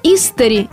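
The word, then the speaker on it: исстари: Но, к нашему счастью, все авторитетные словари единодушны. «Истари» – ударение на первый слог. Второго варианта нет и быть не может.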